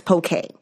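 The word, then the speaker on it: poké: So I think poke is, and it is spelled P-O-K-E. It has nothing to do with Pokemon. – Right. – And it is pronounced poke. – Mm-hmm. – We put the accent on the E because with Canadians, I think we're familiar with the accents and pronouncing it poke. And it just makes it easier.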